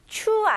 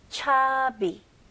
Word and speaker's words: chubby: 발음은 cha, 추 아니에요. chubby, chubby. 그래서 b가 두개 있는 거예요. 오케이? 그거 헷갈리지 않기 위해서. B가 하나였으면 추비나 근데 B가 두 개니까 차비 차비 오케이 very good 오케이 상대적으로 긍정적인 의미로 토실토실한 이라는 뜻을 가지고 있습니다.